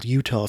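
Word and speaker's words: Eutaw: He was from a town in Alabama called Utah. It's not spelled like the state Utah, by the way. It's E-U-T-A-W.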